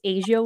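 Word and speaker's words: A'ja: I could be wrong, but I've heard Wilson's name pronounced Asia Wilson.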